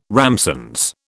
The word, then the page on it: ramsons: British English